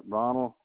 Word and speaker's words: Ronald: Ronald, R-O-N-A-L-D, Brandenburg, B-R-A-N-D-E-N. B-U-R-G.